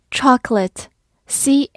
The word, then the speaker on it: chocolate: Chocolate，c h o c o l a t e，chocolate，c h o 发 ch o ch，中间的 c 发 k。 o 不发音，l a t e 发 lit，chocolate，或者也可以发 chocolate，字母 a 发弱读的 a 也是可以的。